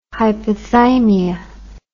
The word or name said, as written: hypothymia